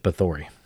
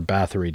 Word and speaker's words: Bathory: Or, as most of us in the world would call it, a poor man's Elizabeth Bathory. Or Bathory, depending on how you pronounce her last name.